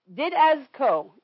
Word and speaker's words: didasko: This word teach, for those of you taking note, is in the original Greek, is didasko, D-I-D-A-S, the emphasis on the A-S, K-O. K-O.